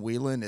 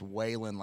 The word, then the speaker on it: Whelan: It's spelled Whelan, like Whelan Foundries here locally, so we're used to, as locals, saying Whelan. It's Whalen, like Whalen Jennings.